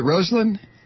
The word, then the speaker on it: Rosalynn: How do you say it? Is it Rosalynn? Rosalynn. That's right. It's Rosalynn Carter.